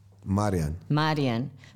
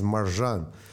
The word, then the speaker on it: Mirjan: Marian. – But it's written M-I-R-J-A-N. And it's pronounced Marian? – Yes, it's pronounced Marian. This is original. Normally, his legend is Marjan.